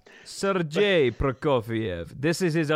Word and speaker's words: Sergei Prokofiev: and I have now the correct pronunciation. – I was given one as well. – Sergei Prokofiev.